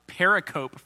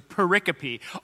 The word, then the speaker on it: pericope: It's spelled like periscope without an S. So you'll probably mispronounce it pericope for a long time, like I did, until you hear someone else say it and you go, oh, okay. So pericope.